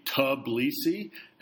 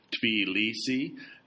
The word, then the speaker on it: Tbilisi: Should it be Tbilisi, as she heard it said by a journalist she knew who had a Georgian boyfriend, or was it pronounced with a tiny shred of a T sound flickering ahead of the B, like Tbilisi, as Russians the author met in Moscow called it?